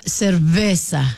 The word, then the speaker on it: cerveza: Et là, j'ai googlé, j'ai demandé à ChatGPT, tout ça, puis ça a l'air que le V en espagnol, il se prononce presque comme un B. Là, on va l'essayer. Votre bouche est fermée au début. Fait que ça serait una cerveza.